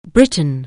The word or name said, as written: Britain